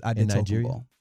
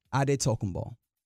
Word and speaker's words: Adetokunbo: And I'm pronouncing his name the way that it originally was pronounced. Adetokunbo. – Yeah. Say, I stepped on. Go ahead. – Adetokunbo. – Adetokunbo. – Yeah. – That's how you pronounce it in Nigeria, but yeah.